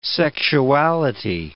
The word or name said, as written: sexuality